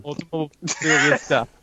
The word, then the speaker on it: Automobilista: Automobilista.